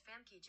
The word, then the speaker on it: Famke: That's pronounced Famke Janssen. Oh, it's pronounced Famke. I was saying it right the first time.